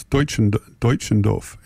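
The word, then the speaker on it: Deutschendorf: Bob Middleton tells me how to pronounce it. It's Dutchendorf.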